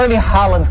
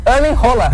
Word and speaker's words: Erling Haaland: Porque não é Erling Haaland que fala o nome dele. – Como é que fala? – Rola. Erling Rola.